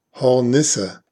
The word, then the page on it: Hornisse: /hɔrˈnɪsə/